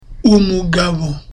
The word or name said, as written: umugabo